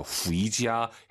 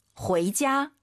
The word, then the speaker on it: 回家: 집에 가다 ui 사이에 에 넣어야 돼요. 후이지야 해도 알아듣겠지만 정확한 발음. – 回家.